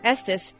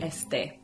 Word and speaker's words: este: The Rio pronunciation would be este. And the Portugal pronunciation would be este. So you have the same word that means the exact same thing, and you have four different ways to pronounce it.